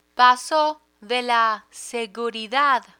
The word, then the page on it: paso de la seguridad: spanish